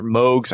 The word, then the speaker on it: Moog: She did a lot of electronic music with Moogs or Moogs. I don't know how they're pronounced. – Moog. – Yeah. Moog.